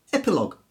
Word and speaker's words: epilogue: Words that end G-U-E or L-O-G-U-E, in those cases, the U is silent and it hardens the G. So colleague, league, tongue, catalogue, dialogue, analogue, and indeed, epilogue.